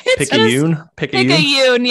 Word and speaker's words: Picayune: The Times, I'm going to say this wrong for sure, but it's Picayune, possibly. Yeah. – You guys are both hooked on phonics. – Picayune, yeah.